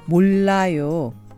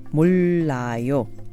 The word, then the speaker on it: 몰라요: After you pronounce the first syllable 몰, find your tongue right behind your upper teeth, and then try saying la of La La Land. Then add yo, so you get 몰라요.